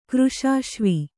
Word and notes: ಕೃಶಾಶ್ವಿ: Kannada

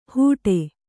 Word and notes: ಹೂಡೆ: Kannada

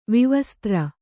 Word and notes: ವಿವಸ್ತ್ರ: vivastra

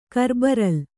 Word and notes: ಕರ್ಬರಲ್: karbaral

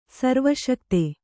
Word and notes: ಸರ್ವಶಕ್ತ: Kannada

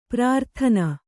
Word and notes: ಪ್ರಾರ್ಥನೆ: prārthane

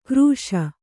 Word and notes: ಕರ್ಷ: karṣa

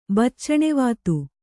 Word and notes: ಬಚ್ಚಣೆವಾತು: Kannada

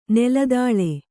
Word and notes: ನೆಲದಾಳೆ: Kannada

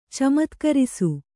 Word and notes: ಚಮತ್ಕರಿಸು: kannada

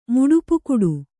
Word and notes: ಮುಡುಪುಕುಡು: Kannada

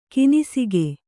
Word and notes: ಕೊನೆಸಾಗು: konesāgu; Kannada

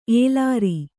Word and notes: ಎಲರು: kannada

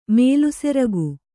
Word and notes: ಮೇಲ್ಸೆರಗು: mēlseragu

